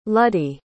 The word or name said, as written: luddy